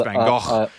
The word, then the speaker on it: Van Gogh: I think your dad shouted at me last time when I pronounced it wrong. And now whenever I say Van Gogh, I think of your dad telling me. – Yeah, it's Van Gogh. I love Van Gogh. – Van Gogh, that's it. You just said it right.